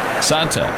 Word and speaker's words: Santa: He is right-hander Alimber Santa, spelled like Santa.